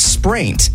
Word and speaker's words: spraint: What is spraint? S-P-R-A-I-N-T.